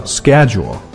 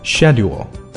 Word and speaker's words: schedule: 注意美音发作在这个词上面美音和英音的差别非常大美音读作 schedule 英音读作 schedule.